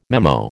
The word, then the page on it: memo: American English